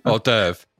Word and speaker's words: hors d'oeuvre: How do you pronounce that? – Hors d'oeuvre. – Hors d'oeuvre. That's it.